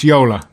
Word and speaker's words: Jolla: And she told me the Finnish word is pronounced Yolla. That it means like boat. It's like a small boat,